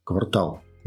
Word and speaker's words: квартал: Квартал В, квартал Д, самый первый и так далее. Это слово стало массовым. Оно массово, оно всегда используется с, с ударением таким ненормативным.